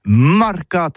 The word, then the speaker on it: marcat: Eh bien, d'ici à toutis, mais le mercat de l'Uni ou le mercat des rebelles, mais en gascogne toulousano, c'est prononcé sur les marcat, avec un A, marcat.